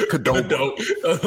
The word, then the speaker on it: Qdoba: I couldn't even tell you the last time I seen a Qdoba. I don't even know if I pronounced that right. It's Qdoba.